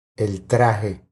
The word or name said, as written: El traje